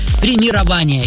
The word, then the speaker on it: премирование: Согласно орфоэпической норме русского языка, ударение в нем все-таки следует ставить на четвертый слог и говорить правильно «премирование».